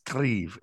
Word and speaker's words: Grieve: Technically, it's Grieve. It's we come from the Scottish borders, so Peebles is a hometown of my family 800 years ago. But Grieve, G-R-E-I-V-E, and it's actually means like the foreman of a farm.